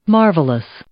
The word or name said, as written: marvelous